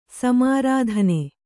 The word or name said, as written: ಸಮಾರಾಧನೆ